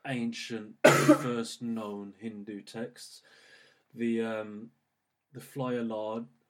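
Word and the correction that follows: fly agaric: I might be saying that wrong. Flyagaric, rather, the Amanita muscaria.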